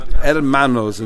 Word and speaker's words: hermanos: Hermanos in Spanish means brothers. Hermanas is sisters, and hermanos is brothers. So when an Englishman reads it, it sounds like Herman, but it's because we don't pronounce the H.